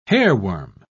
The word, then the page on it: hairworm: British English